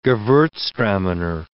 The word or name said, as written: gewurztraminer